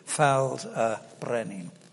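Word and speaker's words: Ffald-y-Brenin: Because F in Welsh is two Fs. If it's only one F, it's pronounced V. So if you're using it to say F, you have two Fs, so double F, A-L-D, Faldebrennen.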